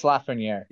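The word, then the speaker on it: Lafrenière: But Lafreniere, that you say right? – Yeah, Alex Lafreniere.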